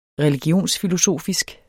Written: religionsfilosofisk

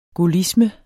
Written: gaullisme

